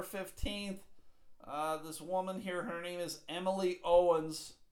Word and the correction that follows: Owings: O-W-I-N-G-S. Owings. Her name is Emily Owings.